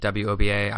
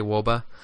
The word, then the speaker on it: wOBA: I mean, some people pronounce it WOBA. I say WOBA.